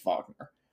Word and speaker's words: Wagner: So it's spelled like Wagner, but it's German. It's Wagner. Yevgeny is pretty rich dude.